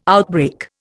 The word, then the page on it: outbreak: Indian